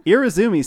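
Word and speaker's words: Irezumi: Irizumi style tattoo. – It's four syllables.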